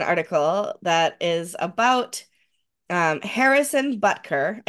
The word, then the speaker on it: Butker: I keep calling his name wrong. I keep saying Butkus, who is a was a another football coach, was Dick Butkus. So I have the name messed up in my head.